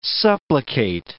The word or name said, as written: supplicate